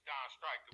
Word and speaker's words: Don Stryke: Yeah, the infamous Don Stryke.